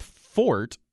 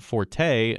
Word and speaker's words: forte: And it does cause problems, as we talked about with Fort. People are, of course, pronouncing it Forte, and there's no accent to tell us one way or the other. Same with cash, C-A-C-H-E.